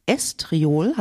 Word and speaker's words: Estriol: Und da gibt es auch das Estriol, habe ich das richtig gesagt? – Genau.